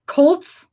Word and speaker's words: cult: You said like C-O-L-T, like cults, like cults. – Like cult 45. – Sorry. I'm like cults with a U.